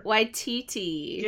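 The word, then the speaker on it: Taika Waititi: Takoa Takiki. – Takoa Takiki. – I know I'm butchering it. – You got it. – Taika, like, you're right there. – Waititi.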